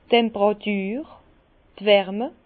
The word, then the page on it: d' Temperatur, d' Wärme: Bas-Rhin; Herrlisheim